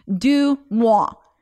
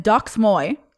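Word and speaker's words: DeuxMoi: It's Du Mois, because I've been calling it Dox Moy.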